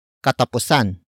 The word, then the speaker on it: katapusan: In this example, sa is followed by katapusan. Katapusan. Katapusan. Katapusan is spelled as K-A-T-A-P-U-S-A-N.